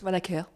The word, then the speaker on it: Vanacker: Voilà, donc j'ai en face de moi Christine Vanacker, c'est comme ça qu'on prononce Vanacker, oui.